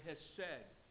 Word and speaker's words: hesed: The word loving kindness here is a translation of the important Hebrew word hesed. It's spelled H-E-S-E-D.